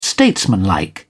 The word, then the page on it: statesmanlike: British English